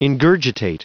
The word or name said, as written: ingurgitate